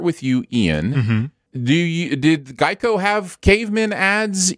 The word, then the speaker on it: Geico: Even though it's pronounced Geico, their mascot is now a gecko.